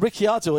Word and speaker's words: Ricciardo: And Ricciardo is the right way to say it. If you hear Ricciardo. – Ricciardo, yeah. Yes.